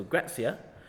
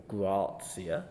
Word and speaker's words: Grazia: Now, ACP magazine's title Grazia, or Grazia, depending how one wants to pronounce it, has been the subject of much discussion since its Australian launch in 2008.